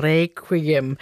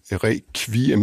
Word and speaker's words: requiem: Der siger man requiem med kv, ligesom på dansk, og der er mange andre lyde, der bliver udtalt anderledes.